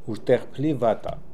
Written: Urdekhplifata

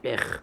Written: ekh

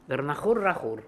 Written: Gornahoor Rakhorkh

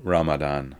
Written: Ramadan

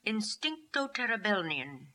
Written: Instincto terebelnian

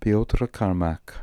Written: piotrkarniak

